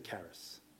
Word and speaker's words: charis: Okay, I had to stick with the Greek in order to maintain the hard C alliteration, right? Culture, covenant, charis.